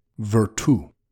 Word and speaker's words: virtu: These quotations both allude to an important word for Machiavelli, virtu, V-I-R-T-U, with an accent over the U. It sounds like the word virtue, but its meaning couldn't be further away.